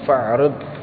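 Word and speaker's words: fa'rid (فاعرض): Fa idza waradat 'alaika kalimatun fa'rid atau fa'rid, bukan a'rid ya, fa'rid, karena ada a'rid atau i'rid, Nah, di situ adalah i'rid dari 'adad 'arada ya'ridu i'rid. Karena dari mana kita tahu bacanya fa'rid di situ? Karena tidak ada hamzah di atas alif.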